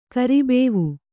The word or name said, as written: ಕರಿಬೇವು